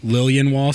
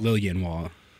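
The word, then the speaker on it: Liljenwall: Lilienwall, so the J is just a Y. – Lilienwall. – Yeah. – Okay, I would have said Lilienwall and just kind of skipped over the J. – That's what a lot of people do, or people just pronounce the J. That's the most common one.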